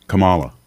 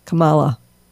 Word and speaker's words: Kamala: Well, because Joe Biden picked Kamala Harris, and I'm just thrilled and happy, and I really have to practice saying her name. It's Kamala. – Kamala.